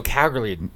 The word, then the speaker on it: Calgary: I said Calgary. I pronounced the L hard. – Calgary. – Calgary. – Calgary. – You know that's how you say it? – Really?